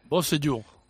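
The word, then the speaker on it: Beausejour: Bosellur era. – Beau con B. Sejour. – Jean Bosellur, este. – Bosellur.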